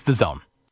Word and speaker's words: DAZN: The pronunciation of that channel is The Zone? – I believe so. DZN. But it's The Zone.